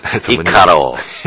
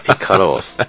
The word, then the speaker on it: Icarus: 嗯，但是在这儿念的时候，我们就必须要念那个日文发音，Icarus，是吧？Icarus。